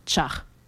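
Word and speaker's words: ĉar: Alors ça s'écrit C accent circonflexe AR et qui, ça veut dire car ou parce que. En fait, la seule chose qui change du point de vue de l'écriture avec le français, bah, c'est l'accent circonflexe. Et donc ce son se prononce ch, donc char.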